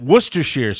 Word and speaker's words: Worcestershire: And when I heard that Worcestershire sauce, and that's the correct pronunciation, incidentally, can go into shrimp, I'm like, what the hell is this?